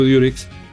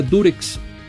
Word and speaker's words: Durex: la marca Durex, así se llamaba Durex, no Durex. Lo que pasa es que nosotros pronunciamos, al decir Durex, pronunciamos la U como se pronuncia en inglés, U, okay.